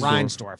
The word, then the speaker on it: Reinsdorf: Reinsdorf.